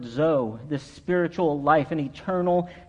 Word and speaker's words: zoe: This word life in the Greek, it's a word pronounced Zoe, Z-O-E.